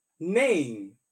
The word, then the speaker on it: Nain: Now, it is spelled N-A-I-N, but pronounced Naim, N-A-I-M. Did y'all hear that? It's spelled Naim, N-A-I-N, but pronounced Naim, N-A-I-M.